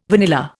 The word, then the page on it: vanilla: Indian